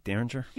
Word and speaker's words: Derringer: Derringer? – Yeah.